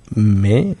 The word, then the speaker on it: mai: Es que se escribe mai con a y con i latina, pero es como me. – Sí, creo, si no me equivoco es me. – Me, ajá, es tal como cual.